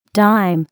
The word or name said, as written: dime